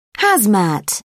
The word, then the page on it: hazmat: British English